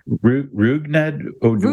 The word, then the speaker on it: Rougned Odor: Ruvidod, Ru, how do you say his words? Odor. – Rougned Odor. – Rougned, Rougned Odor.